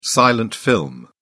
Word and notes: silent film: British English

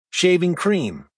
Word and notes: shaving cream: American English

